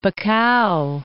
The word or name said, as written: Bacau